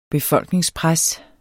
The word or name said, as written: befolkningspres